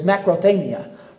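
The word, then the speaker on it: macrothamia (μακροθυμία): And I took Greek, and my Greek isn't all that good, but I can pronounce this word as macrothamia.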